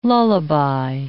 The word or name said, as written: lullaby